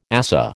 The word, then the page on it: ASA: American English